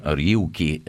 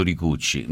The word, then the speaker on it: Ryuichi: come si pronuncia, Ryuichi, non come ha detto mia moglie, ha detto Ricucci, non c'entra niente, Ricucci no.